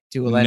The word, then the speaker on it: Doolan: Doolin. No, that's how you pronounce it.